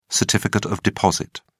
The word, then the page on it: certificate of deposit: British English